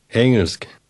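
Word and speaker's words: engelsk: حرف جی در وسط آن محو شد. چگونه میگویید انگلیسی؟ – انگلسک.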